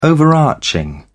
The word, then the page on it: overarching: British English